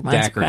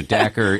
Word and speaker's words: Dacre: I don't know how you pronounce his name. – Dacre Keltner. – Okay. So Dacre, yeah.